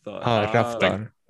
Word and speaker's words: Raftaar: Lathad? – Raftad, like R. – Raftad. – Ruff, like rough. – Okay. – Raftad. Yeah, there you go.